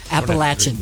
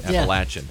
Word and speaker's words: Appalachian: And I was like, did I say that right? – Uh-huh. – You made me sweat. You said it right. – Appalachian. Yeah. Yeah. – Appalachian.